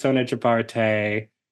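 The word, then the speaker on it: Sona Jobarteh: As I was saying earlier, we have part one of an amazing kinfolk with the incredible, wonderful artist, Sona Jabarte. – Yes. And, yes. Am I saying that right? – Okay, you are.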